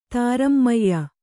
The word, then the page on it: ತಾರಮ್ಮಯ್ಯ: tārammayya; Kannada